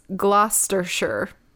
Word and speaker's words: Gloucestershire: A bald baby boy was born in, and I spelled it out phonetically for myself because it looks like Gloucestershire, but it's Gloucestershire.